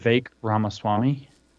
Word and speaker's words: Ramaswamy: Could you pronounce the author's name again for me? Because I can't. – Vivek Ramaswamy. – Ramaswamy, yeah, Vivek Ramaswamy. – V-E-K, and Ramaswamy is spelled the way it sounds, actually.